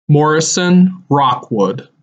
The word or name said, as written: Morrison Rockwood